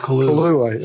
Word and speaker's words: Colu: If he's from the original Brainiac world, Cola, I forgot the name of it. – Always pronounce it Kalu. C-O-L-U.